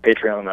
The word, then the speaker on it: Patreon: And now, the blogging has become part of what you're doing with a group called, is it pronounced Patreon? – Yes, Patreon, I believe, is the proper pronunciation.